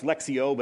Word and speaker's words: Lectio: It's pronounced Lexio, but it's spelled L-E-C-T-I-O 365, done by 24/7 Prayer International.